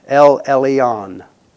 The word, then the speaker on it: El Elyon: E-L, capital E-L, capital E-L-Y-O-N, El Elyon.